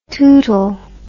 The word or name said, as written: tootal